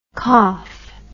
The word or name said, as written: koff